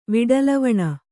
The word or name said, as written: ವಿಡಲವಣ